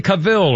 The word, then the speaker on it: Cavill: Our birthday list includes Henry Cavill, or Cavill. Henry Cavill is how I've heard it pronounced.